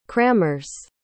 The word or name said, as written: crammers